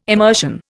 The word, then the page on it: immersion: Indian